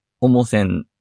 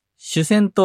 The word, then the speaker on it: 主線: 主人公の主に線で、重せんと読みます。主線と読む人もいます。